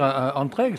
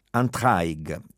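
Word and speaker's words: Antraigues: La pronuncia giusta non è quella parigina o del francese standard, ma quella della lingua d'oca. Entraigue, tra le acque, tra i fiumi.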